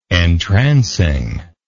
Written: entrancing

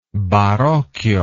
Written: barocchio

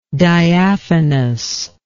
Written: diaphanousness

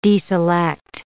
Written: deselect

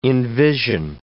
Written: envision

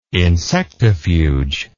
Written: insectifuge

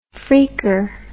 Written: freaker

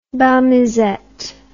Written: bal musette

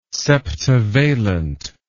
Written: septavalent

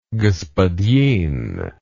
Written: gospodin

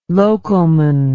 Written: locoman

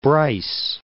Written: Bryce Canyon National Park

